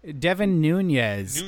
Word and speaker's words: Nunes: Speaking of personal friends of Trump getting favors, Representative Devin, is it pronounced Nunes? – Devin Nunes. – Nunes.